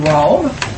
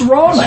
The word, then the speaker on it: Roma: When we went to Rome, it's not Rome. And why didn't people even call it Rome? It's Roma. It's two syllables. It doesn't put an E on it. It's an A on the end of it.